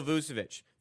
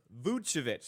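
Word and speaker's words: Vučević: For Nikola Vucevic, 15, or Vucevic, sorry, I'll say it correctly for Pips here, 15-plus points and 10-plus rebounds.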